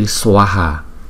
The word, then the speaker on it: Swaha: Bởi vì cái chữ A có dấu gạch ngang trên đầu cho nên chúng ta đọc rõ ra là chữ Swaha, Swaha, Swaha.